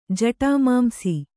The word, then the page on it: ಜಟಮಾಂಸಿ: jaṭamāmsi